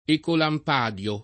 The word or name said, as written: Ecolampadio